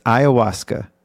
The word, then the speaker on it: ayahuasca: It's ayahuasca.